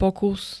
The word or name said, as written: pokus